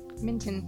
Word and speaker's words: Minton: Is it Minton or Mitten? – Minton. M-I-N-T-O-N.